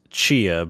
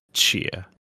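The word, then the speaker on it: Tchia: Surely it's just Chia, but you start with your tongue on the top of your mouth like you're pronouncing a T. So it's Chia.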